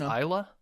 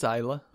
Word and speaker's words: Ajla: I think it's Ayla. – Ayla? – A-J-L-A. – A-J-L-A. Ayla? – I think it's Ayla. – Ayla. – Probably with a bit more of an accent.